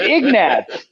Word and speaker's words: Ignatz: That's how you pronounce that name. – The name is so good, Ignatz.